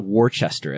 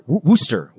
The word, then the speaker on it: Worcester: It's not Worcester as it appears. – It's yeah. Wor- Worcester, Worcester, Massachusetts.